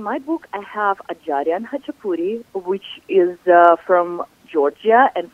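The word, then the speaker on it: Khachapuri: You are trending, my friend, because I want to segue to Hachapuri. Is that the proper pronunciation? – Yes. – Okay, Hachapuri.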